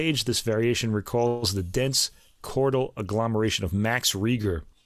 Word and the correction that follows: Reger: or I should say Rega, for the German pronunciation.